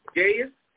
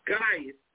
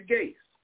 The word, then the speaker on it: Gaius: Now, it's pronounced Gaius in English, or Gaius if you want to pronounce it in the Greek. I'm English. I'm English. American. I'm going to say Gaius, whom I love in truth.